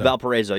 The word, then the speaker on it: Valparaiso: Valparaiso, yeah, uh-huh.